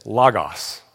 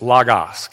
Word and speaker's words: logos: This word, there's no other way to say that, is a word in the Greek that is this, logos. Everybody say logos. We have a tendency in the English to pronounce it logo, like the Nike logo, but it's logos, okay?